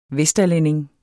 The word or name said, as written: vesterlænding